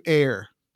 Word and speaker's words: err: I have the courage to err. Err is the proper pronunciation.